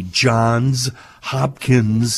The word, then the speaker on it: Johns Hopkins: Thank you for saying it correctly. – Center for Psychedelic and Consciousness Research. I could do an hour on why we have to call it Johns Hopkins.